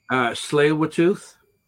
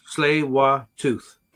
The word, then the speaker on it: Tsleil-Waututh: It's, think of a sleigh. – Okay. – Tsleil-Waututh. – Okay. – So Tsleil-Waututh. – Okay. The Tsleil-Waututh Nations to develop recommendations for the implementation of UN Declaration on the Rights of Indigenous Peoples, or UNDRIP.